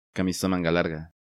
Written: Camisa manga larga

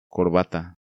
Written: Corbata

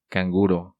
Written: Canguro

